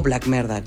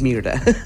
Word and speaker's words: Black Merda: La canción que sigue es de un dueto poco perdido en la historia, infravalorado definitivamente, y se llaman Black Mirda, o Black Merda, como quieran pronunciarlo, pero es Mirda.